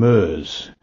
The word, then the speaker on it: Merz: Those who work with him call him Merz, to rhyme with whirs and stirs.